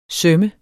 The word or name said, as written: søm